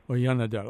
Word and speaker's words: Oyanadel: That is Vern Oyandel. How did I do pronouncing your name? – It's actually pretty close. My full name in English is pronounced Werner, and my last name is Oyanadel. – Oyanadel.